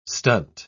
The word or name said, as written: stunt